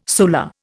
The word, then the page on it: solar: Indian